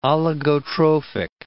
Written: oligotrophic

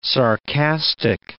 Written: sarcastic

